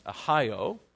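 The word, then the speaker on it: Ahio: One of them was Uzzah, and the other was, I think it's pronounced Ohio.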